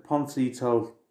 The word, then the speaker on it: Pontcysyllte: Yeah, Poncito. No, Pont. See, ta. Poncito Chapel Tea Rooms. Right, I spell it, and then. So I'm giving you a warning now, fair warning, write this down or type it straight in. It's PONTCYSYLLTE.